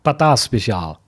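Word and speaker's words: patatje speciaal: Ich habe ja vorhin auch erst das erste Mal in meinem Leben Patata Special, sag du es nochmal. – Patata Special. – Ja, genau, das war die richtige Special. Richtige holländische Aussprache hier.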